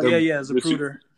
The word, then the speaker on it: Zapruder: Yeah, so he's on the Zapruder films. I think that's how you say it. – Oh, Zapruder.